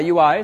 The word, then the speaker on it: YUI: So Yahoo has this package called the Yahoo User Interface, so YUI, which is how they pronounce it. Frankly, I always want to say UI because it's quicker, but YUI is how they like to pronounce it.